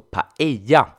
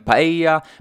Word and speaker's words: paella: Och den här överprisade risgrytan stavas ju P-A-E-L-L-A. Och med tanke på det vi precis har lärt oss om dubbel L så uttalas det alltså paella och inte paella.